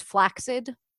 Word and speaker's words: flaccid: Story, did you guys know that the word flaccid should be pronounced flaccid? – Huh.